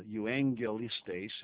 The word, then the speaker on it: euaggelistes: Poimen is P-O-I-M-E-N. P-O-I-M-E-N. Poimen. Eugelistes. I'll spell it slowly. E-U-A-G-G-E-L-I-S-T-E-S. Eugelistes. And it comes from euangelistes.